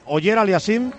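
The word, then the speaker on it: Auger-Aliassime: Ya está todo preparado en esta pista central, calentando Félix Oller Aliassime. Se dice así, ¿eh? Oller Aliassime.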